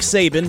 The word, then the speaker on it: Saban: It makes sense that Nick Saban, whose name rhymes with Satan, would be born on Halloween.